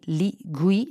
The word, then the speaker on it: Li Gui: Li Gui, non so se si pronuncia così.